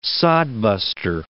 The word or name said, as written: sodbuster